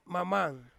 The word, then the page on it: mamang: Ansus